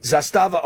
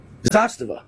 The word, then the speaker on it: Zastava: All right, sponsor of this segment, Zastava Arms. Am I saying that right? – Correct. – Isn't it Zastava? – You're saying it right. Zastava.